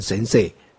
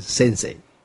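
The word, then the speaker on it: せんせい: 这日本传授佛法的人都称为老师，那日文称为 sensei 啊，台湾人都念成 sensei，那个重音错了啊，应该是 sensei 啊。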